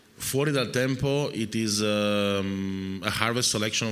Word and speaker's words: Fuori dal Tempo: This is a, correct my pronunciation if I'm wrong, Fuori dal Tempo.